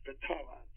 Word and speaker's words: Fathala: They have another one that is very interesting, and that's Batala. But they spell it F-A-T-H-A-L-A. They pronounce it without the H, Batala. Not Batala, but Batala.